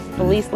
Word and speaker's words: Felice: Yes, Felice LaZae.